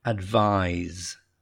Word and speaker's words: advise: Advise. Advise. You got that z sound at the end.